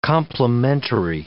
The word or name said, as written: complimentary